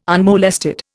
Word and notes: unmolested: Indian

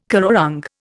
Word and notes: currawong: Indian

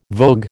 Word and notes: vogue: American English

